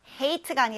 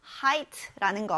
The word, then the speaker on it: height: 여기 H-E-I니까 hate라고 발음하고 싶은 친구들 있을 것 같은데, hate가 아니라 height라는 거 유의하세요.